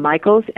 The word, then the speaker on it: Michaels: And Ragani is spelled R-A-G-I-N-I. And Michaels, M-I-C-H-A-E-L-S, as in Sam.